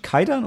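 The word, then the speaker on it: Kwaidan: Also ich glaube, es ist wirklich Kaidan und das übersetzt sich als Geistergeschichte oder Gruselgeschichte.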